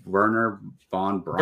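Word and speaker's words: Wernher von Braun: He's going to play Voller, who is inspired partly by real life Nazi turned NASA engineer Werner von Braun. Werner von Braun. I knew to pronounce those W's with like V's, mm-hmm.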